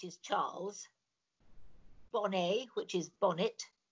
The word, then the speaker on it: Bonnet: I mean, the thing is that Charles Bonnet was Swiss, Genevan, and so I always pronounce it as he would have done.